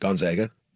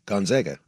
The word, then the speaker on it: Gonzaga: How do you say Gonzaga? – Just like that. – No, say it. – Gonzaga. – Oh, there's a lot of gone in there. – Yeah, Gonzaga.